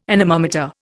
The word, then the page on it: anemometer: Indian